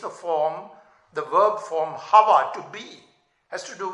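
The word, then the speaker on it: Hava (חוה): And the Hebrews pronounce it with a CH, Hava.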